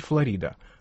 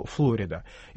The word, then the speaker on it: Флорида: Все-таки штат Флорида в русской транскрипции, ну, в русском произношении принято называть Флорида. Хотя американцы, естественно, называют его Флорида.